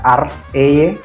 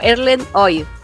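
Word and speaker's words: Erlend Øye: Pero bueno, se llama Erlen Oye, también se pronuncia Ar Eye.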